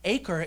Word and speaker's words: Achor: I was like, the Valley of Achor, and Achor is actually how you pronounce it because I had to Google it.